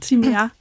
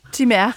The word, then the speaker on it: Timea: Altså, der er udtalefrihed, men jeg udtaler det selv Timea. – Timea.